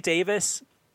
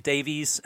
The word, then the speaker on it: Davies: Charlie Davis, or Charlie Davies, as Americans say, it's interesting too because he's going to be on some of the Bundesliga coverage for Fox.